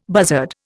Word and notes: buzzard: Indian

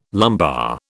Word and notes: lumbar: British English

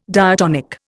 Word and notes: diatonic: Indian

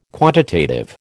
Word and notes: quantitative: American English

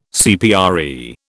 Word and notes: CPRE: British English

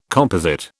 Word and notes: composite: British English

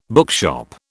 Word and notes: bookshop: British English

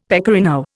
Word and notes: pecorino: Indian

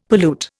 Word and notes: pollute: Indian

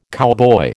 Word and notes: cowboy: American English

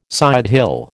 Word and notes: sidehill: American English

